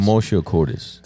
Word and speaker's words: commotio cordis: Commotio cordis.